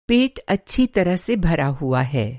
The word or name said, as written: अच्छी तरह से भरा हुआ